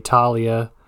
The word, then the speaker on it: Thalia: We get the opening scene where we see Thalia, or as it's pronounced in this movie, Talia, getting murked by monsters just outside Camp Half-Blood.